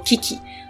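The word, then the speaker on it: 記紀: 两本呢，因为一本是《日本书纪》，一本是《古事记》，它简称“ 纪记”，嘿，而日文就念作 “Kiki”，啊，蛮可爱的哦。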